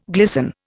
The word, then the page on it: glisten: Indian